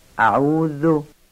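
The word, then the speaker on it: أعوذ: دومیش هم ذاله، ذال و بعد نوک زبانی بگیم، نوک زبانی نازک، اعوذ. ما در فارسی ذالو معمولا زی زنبیلی تلفظ میکنیم. اینجا دقت کنید، باید نوک زبان رو بین دندان ها قرار بدید و نازک و ظریف ذال رو تلفظ کنید. خب اعوذ، بعضیا ذال رو درشت میگن، شبیه زای دستدار میگن اعوذ. از اینم بپرهیزیم، نوک زبانی و نازک تلفظ کنیم.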